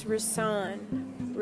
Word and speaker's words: Risaun: It's Risaun. Risaun. So think of wrist. Wrist, Risaun, Risaun.